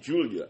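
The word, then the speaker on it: Giulia: We're butchering the Gilia. Now seeing that name and hearing other people from other wrestling podcast say the name Julia. I think that's what that is.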